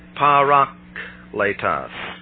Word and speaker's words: parakletos: First of all, the word advocate is the Greek word parakletos. And that is the way it is pronounced.